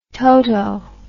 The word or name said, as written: toto